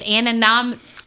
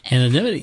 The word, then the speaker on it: anonymity: Anonym, anonymity.